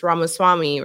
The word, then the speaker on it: Ramaswamy: because if they want a guy whose last name is Ramaswamy, right, is that how you say it? – Yeah.